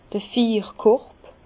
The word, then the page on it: d'r Fiirkorb: Bas-Rhin; Herrlisheim